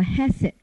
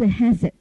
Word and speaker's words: hesed: 那么恩慈这个字，我现在把那个希伯来文的读音给大家，那个刮胡里面就是希伯来文读音，叫做 hesed 这个字啊，希伯来文这个字读音是 hesed。